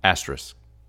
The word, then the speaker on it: asterisk: A-S-T-E-R-I-S-K. – Asterisk. – Say it again. – Asterisk. – So you're not pronouncing the K.